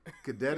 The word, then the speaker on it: Cadet: What's the proper way of saying that? – Cadet is the proper way? – Yes, sir.